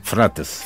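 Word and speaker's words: Fırat: Los ricos grandes ríos, bueno, yo fui en la Mesopotamia, fui al Éufrates, y se dice Frotes. En turco. – ¿Cómo se dice? – Frates.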